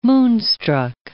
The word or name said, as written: moonstruck